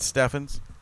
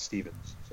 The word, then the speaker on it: Steffens: It's Mr. Matt Steffens. I said it right, correct? – You did. You said it correctly. – Good. See? – People call me Matt Stevens.